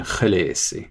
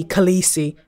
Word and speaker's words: Khaleesi: So I decided to honor that, which gives us the pronunciation Khaleesi.